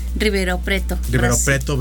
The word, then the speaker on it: Ribeirão Preto: Ribeirão. – Ribeirão Preto, Brasil.